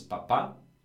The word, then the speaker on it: papà: One is papà and one is papa. And the only difference is a little accent on the last A.